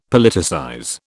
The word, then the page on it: Politicize: British English